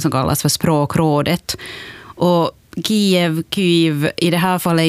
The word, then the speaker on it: Kyjiv: Huvudstaden Kiev ska nu utstavas K-Y-J-I-V och det ska uttalas Kiev med betoning på första stavelsen.